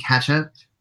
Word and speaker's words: ketchup: Not catsup. Not catsup. – Catsup. But say it again. – Ketchup. – What's that word? Ketchup. Okay. Yeah.